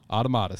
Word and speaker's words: Automata: Automata, thank you.